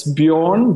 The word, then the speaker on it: Bjorn: It's Bjorn, B-J-O-R-N, Moerman, M-O-E-R-M-A-N.